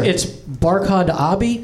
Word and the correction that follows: Abdi: Barkhad, you got right, but it's Abdi.